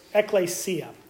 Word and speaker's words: ekklesia: But the church comes from the Greek word that's ekklesia. That's how you pronounce it in Greek.